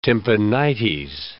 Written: tympanites

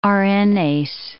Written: RNase

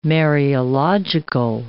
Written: Mariological

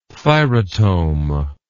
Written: thyrotome

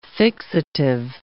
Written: fixative

